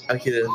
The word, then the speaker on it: Akira: Yeah, it's Akira.